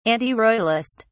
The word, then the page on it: antiroyalist: British English